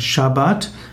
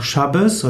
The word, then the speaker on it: Shabbat: Sabbat, auch geschrieben Shabbat, eigentlich ausgesprochen Shabbat oder auch Shabbos oder Shabbos, heißt Ruhetag oder Ruhepause.